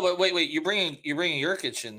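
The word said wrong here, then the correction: Nurkić: And Nurkic, you are, right? – You are.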